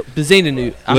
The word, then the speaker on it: Bezuidenhout: So Bezuidenhout.